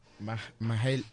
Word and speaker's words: Mahalia: Mahalia.